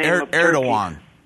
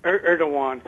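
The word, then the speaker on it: Erdoğan: Erdogan. – Erdogan.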